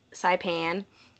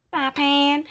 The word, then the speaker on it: Saipan: So, the first guy they interview is a man who claims to have found Amelia's briefcase after a battle in Saipan is how they pronounce it, so. – Saipan.